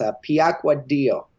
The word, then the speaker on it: Piacquadio: It's very Italian. It's Piacquadio.